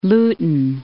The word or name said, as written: Luton